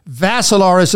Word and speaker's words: Vassilaris: Dimitri is with three I's, D-I-M-I-T-R-I. Vassilaris is the last name. Rhymes with Assilaris, but you add a V.